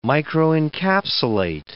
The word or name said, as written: microencapsulate